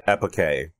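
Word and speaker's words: epoché: So let's talk about the bracketing, the epike. Is that how it's pronounced? That's how it's pronounced in the ancient Greek. – Yes.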